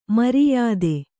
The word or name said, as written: ಮರ್ಯಾದೆ